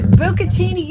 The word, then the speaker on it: bucatini: Bucatini, you said it right.